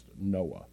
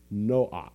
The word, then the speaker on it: Noach: It's not pronounced Noah in Hebrew. In Hebrew, it would be pronounced Noach. And so at the very end would be a hard CH sound in Hebrew.